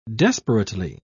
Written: desperately

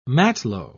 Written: matelot

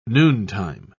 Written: noontime

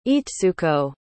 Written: itsuko

